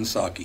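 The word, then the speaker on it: Psaki: I don't know if you pronounce the P. I assume not. – It's pronounced Jen Saki.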